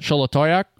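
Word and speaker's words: Xolatoyac: Zolotoyak. – Full send. – Sholotoyak. I think that's probably the way it's pronounced. – That sounds about right. – Sholotoyak.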